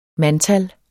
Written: mandtal

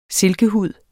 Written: silkehud

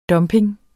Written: dumping

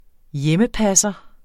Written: hjemmepasser

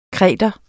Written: kretenser

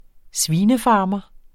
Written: svinefarmer

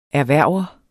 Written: erhverver

